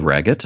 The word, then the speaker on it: Raggett: It can be found at my name, Ned Raggett. That's spelled R-A-G-G-E-T-T.